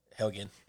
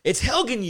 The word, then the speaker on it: Helgen: Helgen. – It's Helgen, you idiot. It's Helgen. Listen, it's Helgen.